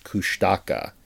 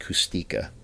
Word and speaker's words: Kushtaka: It's spelled the phonetic... If you pronounced it phonetically, it looks like Kushtaka. But apparently it's pronounced Kustika.